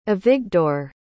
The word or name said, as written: avigdor